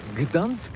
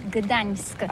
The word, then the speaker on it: Gdańsk: Ça s'écrit G-D-A-N-S-K. On dit Gdansk ou Dansk, on prononce le G ou pas? – Oui, c'est pas très facile pour des étrangers, mais remarque bien que la langue polonaise, c'est la langue où on prononce chaque lettre qu'on écrit. C'est pourquoi on dit Gdansk.